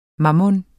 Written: mammon

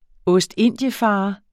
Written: ostindiefarer

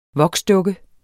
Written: voksdukke

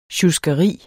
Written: sjuskeri

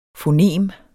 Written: fonem

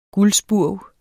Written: gulspurv

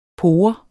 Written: pore

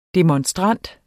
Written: demonstrant

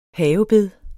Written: havebed

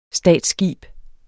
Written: statsskib

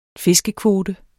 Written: fiskekvote

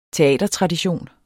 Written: teatertradition

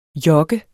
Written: jokke

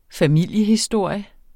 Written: familiehistorie

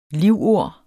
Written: livord

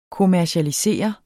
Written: kommercialisere

